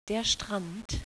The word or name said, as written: strand